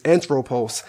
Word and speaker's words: anthropos: It's anthropos.